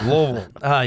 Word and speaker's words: Louisville: And look, all my Kentuckians, I said it right. I didn't say Louisville. – Louisville, yeah. – Louisville. You got to relax the jaw.